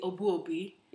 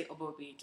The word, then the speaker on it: Obuobi: So in Ghana, my last name, you would say Obuobi. – Yeah. – But here, I just say Obuobi.